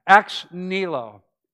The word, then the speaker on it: ex nihilo: And actually, it's three syllables. A lot of people say it quickly, so it sounds like two. Ex nihilo.